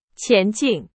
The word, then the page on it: 前进: Chinese